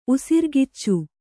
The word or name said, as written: ಉಸಿರ್ಗಿಚ್ಚು